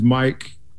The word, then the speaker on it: Mic: My name is Mic Crenshaw, spelled M-I-C, as in short for microphone.